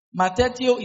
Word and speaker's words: matheteuo: Matetio is spelled M-A-T-H-E-T-E-U-O. Matetio. M-A-T-H-E-T-E-U-O.